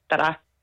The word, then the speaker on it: Zara: Hvorfor ved du, hvordan man udtaler navnet på den tøjkæde, som staves Z-A-R-A? – Fordi jeg har spansk i gymnasiet, og der lærte vi, at Z på spansk udtales til H, som egentlig lyder mest som et D, og R udtales faktisk også lidt som et D.